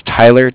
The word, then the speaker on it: Tyler: My name is Tyler. T Y L E R. And I'm calling with Cardiff. It's spelled C-A-R-D-I-F-F, as in Frank Frank.